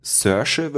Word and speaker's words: Saoirse: Der hat jetzt wieder einen äh, Vampirfilm gemacht, in dem äh, du hast damals gesagt, letztes Jahr sie, Saoirse, würde man das aussprechen, Saoirse Ronan, erinnerst Saoirse.